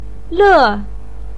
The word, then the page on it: le4: pinyin